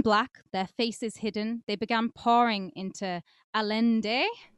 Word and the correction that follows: Allende: No, it's which Allende, is... like a Y. – Oh, Allende.